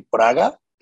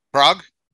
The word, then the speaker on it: Prague: How do you say Praga? – Prague? – Prague, yeah. – Prague, okay.